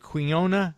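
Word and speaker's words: quinoa: This, how do you pronounce it? Quinona? How do you, Meredith likes to make that. I don't know how to pronounce it. – Yeah, you did a good job. – Is that how you pronounce it? – Yes.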